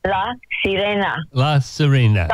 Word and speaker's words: Sirena: Look, I think we should go to another track from your brand new album of yours, A Blessed Unrest, and this is La Sirena, coming up after this message. – La Sirena. – How do you pronounce it? – La Sirena. Oh, yeah, it's a mermaid.